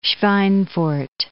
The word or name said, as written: Schweinfurt